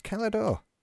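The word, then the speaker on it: Cala d'Or: Was it Calador? Yes, it was. Calador, which is spelled C-A-L space A space D-O-R.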